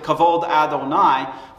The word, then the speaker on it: kavod Adonai: But the first time the Hebrew term, as the Jews pronounce it, kavod Adonai,